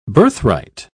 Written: birthright

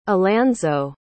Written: alanzo

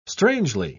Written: strangely

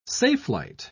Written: safelight

